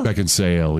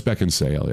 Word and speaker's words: Beckinsale: Is it really Beckinsale? – Beckinsale, yeah. – Have I said it? Have I said it wrong to you? – You sometimes get it right, though. – I don't think I've ever gotten it right because I thought this was a misprint. – No, it's Beckinsale, yeah.